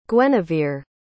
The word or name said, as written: guenivere